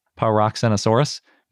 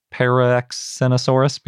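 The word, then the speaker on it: Paraxenisaurus: Maybe it should be pronounced Paroxenosaurus because it starts with the same para as Parasaurolophus. But when I was looking up the Greek pronunciation of paroxen, that's how they say it.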